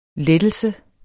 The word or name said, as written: LETTELSER